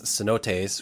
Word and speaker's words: cenotes: I guess what I'm curious about with these cenotes, which thank you for teaching me that that's not pronounced C-note, but...